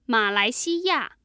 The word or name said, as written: malaixiya